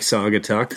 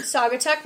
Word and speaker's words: Saugatuck: He was near, I don't know how to pronounce this, maybe you do. It is S-A-U-G-A-T-U-C-K. – Saugatuck. I didn't hear that. Oh, no. I've never heard of that before. Okay. It looks like Saugatuck.